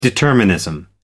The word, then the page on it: determinism: American English